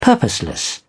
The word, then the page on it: purposeless: British English